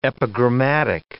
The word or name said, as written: epigrammatic